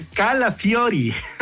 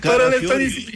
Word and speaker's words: Calafiori: Es Calafiori. – No, yo Calafiori. Pero le di tres veces la oportunidad. – Carapiori, ¿qué es? – Es Calafiori.